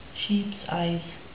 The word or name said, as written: sheeps eyes